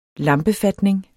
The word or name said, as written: lampefatning